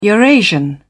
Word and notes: eurasian: British English

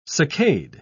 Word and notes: succade: British English

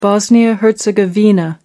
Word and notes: bosnia-herzegovina: American English

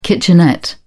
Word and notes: kitchenette: British English

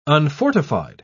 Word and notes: unfortified: British English

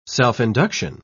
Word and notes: self-induction: British English

